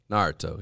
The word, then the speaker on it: Naruto: Naruto.